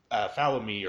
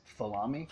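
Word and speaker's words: Folami: It's like, who the hell was this random figure that wants to help out Falami or Falimi? I forget how to pronounce Falami. – F-O-L-A-M-I. I just, in my head, it's Falami.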